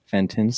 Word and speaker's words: Fenton: Fenton.